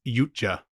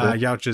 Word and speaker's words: Yautja: I think it's pronounced Yautja. – Yautja. – I looked into it. – Okay, cool. – Yautja is probably fine too.